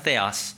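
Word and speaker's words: theos (θεός): It's pronounced theos. The- I know, like theos.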